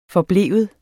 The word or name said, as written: forblive